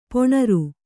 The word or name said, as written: ಪೊಣರ್